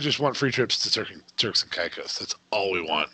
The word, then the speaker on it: Turks and Caicos: Turks and Caicos.